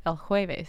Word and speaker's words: El Jueves: I did that very French and completely incorrect. – Man. – Say that again. – El Jueves.